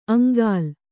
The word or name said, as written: ಅಂಗಾಲ್